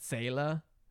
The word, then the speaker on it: Seele: It's German for soul, and it's, I think you'd actually in German pronounce that Seele.